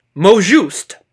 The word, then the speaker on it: mot juste: So our number one word coming to us from November 18th, 2019 is mojoust.